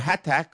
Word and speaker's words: Hatach: She says that she sent someone, Hatach, or Hatach, I believe is the pronunciation,